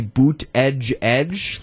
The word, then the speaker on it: Buttigieg: I have seen people close to him before say it's supposed to be boot edge edge, like E-D-G-E-E-D-G-E, like boot edge edge, or Pete boot a judge.